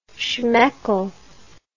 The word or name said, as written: schmeckel